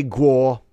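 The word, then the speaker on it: Gou: They'll say Guo, but he misspells it Goh, so that's how I'm going to pronounce it.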